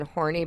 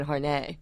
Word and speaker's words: Horney: You know her name is Karen Horney, but I think she pronounced it like Karen Horney. – Oh, yeah. – Neurotically, so that nobody would think that she was, in fact, Horney.